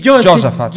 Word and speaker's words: Giosafat: E due, questi due attaccano questa re di Israele, re di Giuda, una piccola di Giosafat, ha detto bene il nome? Sì, Giosafat, e lui in difficoltà.